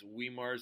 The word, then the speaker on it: Weimar: So, next up, we have another great band, a band called, I believe it's pronounced Weimar, so W-E-I-M-A-R.